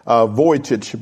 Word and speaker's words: Vujicic: That's his name on the screen, so that you, if you want to look it up, that's his name, because it's pronounced Vujicic, but it's not an English name, so you would need to know how to spell it.